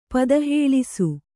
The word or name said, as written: ಪದಹೇಳಿಸು